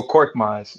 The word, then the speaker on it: Korkmaz: Furkan Korkmaz. – Oh, Korkmaz.